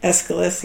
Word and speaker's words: Aeschylus: Aeschylus, yeah.